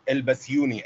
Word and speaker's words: Elbasuni: The problem is my second name is so hard to pronounce. Like, English people have a problem pronouncing it. – Is it the second name I see here on chat? Is it Abdelrahman? – No, not that okay. One. – Pronounce it for me. I want to hear it now. – It's Elbasuni, actually. – Elbasuni. Yep. Elbasuni. Am I saying it right? – Yeah.